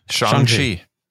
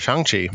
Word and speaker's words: Shang-Chi: I've learned on this show that I don't know how to pronounce it, but so it's Shang-Chi? – Shang-Chi. – Yep. Shang-Chi.